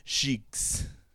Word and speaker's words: Cheeqes: After this introduction to Cassidy at Cheeks, which I just love, it's spelled C-H-E-E-Q-E-S. – That's right, the French way. – Cheeks.